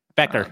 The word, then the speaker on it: Becher: Jonathan Becher. – Becker. – The Shark. Becker. God, I cannot pronounce Jonathan Becker. Every time I try, it's like Becher.